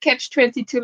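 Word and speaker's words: Catch 2/2: So is it pronounced Catch 2-2 or is it like Catch 22, like the book? – Catch 2-2. – Oh, okay, interesting. – That's how they've been doing it. I think that's why there's a slash in between the 2-2s.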